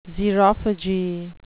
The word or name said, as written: xerophagy